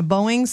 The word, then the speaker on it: Boeing: A United Airlines pilot was flying a Boeing 787.